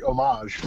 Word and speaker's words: homage: And then she pronounced it properly, homage.